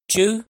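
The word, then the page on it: Tswb: Hmong; last name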